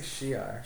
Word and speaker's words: Shi'ar: How do you say it? – Asgard? – No, Shire. – Shire? – I say Shi'ar.